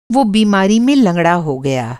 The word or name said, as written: लंगड़ा